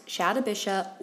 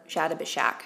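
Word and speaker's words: Shatabhisha: First of all, this nakshatra can be pronounced Shadabisha or Shadabishak. So you may see it spelled and pronounced these two ways.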